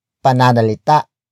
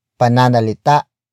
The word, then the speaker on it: pananalita: And that is pananalita. Pananalita. Pananalita. Pananalita is spelled as P-A-N-A-N-A-L-I-T-A. Note the glottal stop at the end.